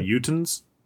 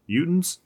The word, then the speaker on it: Utahns: So if it rhymes with croutons, can we just call them Utahns? Like croutons? Utahns? – Maybe, yeah.